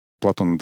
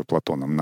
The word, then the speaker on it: Платон: Платон, да, конечно, Платон надо бы было бы говорить, но раз мы привыкли с французским ударением произносить это имя, будем называть его Платоном на русско-французский манер.